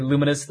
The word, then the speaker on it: Lumines: I call it Lumines, Lumines. – It's spelled Lumines. – It's right. But it's got to be Luminous. Thank you, Zombie 5, for slapping me around on that so I pronounced it correctly.